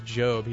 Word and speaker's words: Job: It's Job. He said it rhymes with robe.